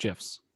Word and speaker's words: GIF: Yeah, it's correctly pronounced GIFs.